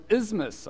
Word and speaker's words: isthmus: An isthmus, okay? It's a word you say with a lisp. It's spelled I-S-T-H-M-U-S, isthmus.